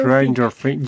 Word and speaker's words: Stranger Things: Y, będziemy rozmawiać o grze Stranger Things, wydany przez... Ale, ale akcent Stranger Things. – Stranger, Stranger, Stranger Things.